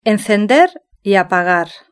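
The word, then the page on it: encender y apagagar: Spain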